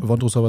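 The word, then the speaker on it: Vondroušová: Wondrusova? Ah, tatsächlich, ist das richtig ausgesprochen? Wondrusova ist richtig ausgesprochen.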